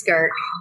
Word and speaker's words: Gurk: Not jerk. It's Gurk.